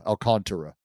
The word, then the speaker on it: Alcantara: How do you say his fucking name? Alcantara? Because everyone uh, says it, it's just not spelled that way. – Not not C H like Alcantara. So Tara. Tara. Alcantara.